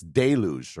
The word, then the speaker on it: deluge: This word is apparently a word that I've been mispronouncing my entire life. – It's deluge, right? – Deluge, okay. – Instead of deluge, whatever. – I think we might have both said deluge.